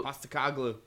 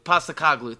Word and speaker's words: Postecoglou: Postecoglou. – Postecoglou, thank you.